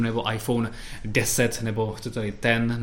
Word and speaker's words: iPhone X: No ale to se nevyslovuje X, to se no. vyslovuje iPhone 10 Česky teda 10.